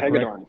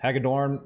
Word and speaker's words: Hagedorn: Hopefully I'm pronouncing that Hagedorn. – Correct. Hagedorn, after graduating from Yale University.